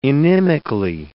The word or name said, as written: inimically